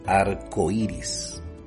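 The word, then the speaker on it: arcoíris: De hecho, el diccionario de la Real Academia Española sugiere que se escriba en una sola palabra: arco iris. Con acento en la primera I, cuando va junto, ¿verdad? Arco iris.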